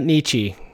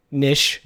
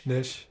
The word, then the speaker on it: niche: It's not Nietzsche. Nish. – Nish. – Yeah. With an SH.